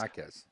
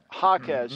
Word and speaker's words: Jaquez: I don't know if I'm saying his name correctly. – Jaime Jaquez. But Jaquez. – Yep. Jaquez, mm-hmm. Yeah. I love that kid as a player, but I got to get his name right, Jaquez.